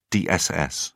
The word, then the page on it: dss: British English